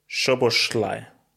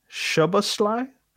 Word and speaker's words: Szoboszlai: Say again, slow. – Szoboszlai. – Szoboszlai? – Yeah.